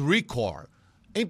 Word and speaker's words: recorde: Pelo amor de Deus, a palavra é paroxítona. Não existe recorde. Existe em inglês record. Em português é recorde. É recorde.